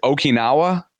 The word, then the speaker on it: Okinawa: Is it Okinawa or? – That's how I say it. Okinawa.